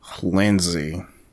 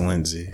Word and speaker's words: Lindsey: Lindsay. – Lindsay. All right, because I never actually knew that. How do you spell that? – L-I-N-D-S-E-Y. – Okay. Is it Lindsay almost? – It's Lindsay.